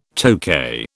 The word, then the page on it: Tokay: British English